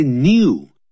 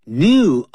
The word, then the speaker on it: new: Do not say new. New, of course, is still right in terms of pronunciation, but usually it is those British people that will pronounce that word new. That's in American English, we say new.A new generation of children.